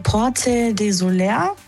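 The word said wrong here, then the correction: Sóller: Ich glaube, es spricht man anders aus. – Porte Soler.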